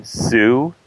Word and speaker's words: Sue: What by a French writer of the 19th century named Eugene Sue. His last name is spelled S-U-E, just like the girl's name.